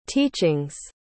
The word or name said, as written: teaching's